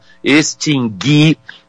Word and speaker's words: extinguir: Não, nunca, nunca se pronuncia o u. adquirir, eu adquiri, você adquirirá, extinguir, ele extinguiu, ele distinguiu, sem a pronúncia do U, nos verbos adquirir, distinguir e extinguir.